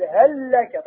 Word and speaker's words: هل لك: تقول هل لك، تنطق لام واحدة مشددة، كيف يسمى هذا الإدغام؟